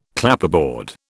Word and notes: Clapperboard: British English

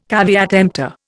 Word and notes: caveat emptor: Indian

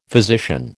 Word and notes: physician: American English

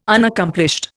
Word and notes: Unaccomplished: Indian

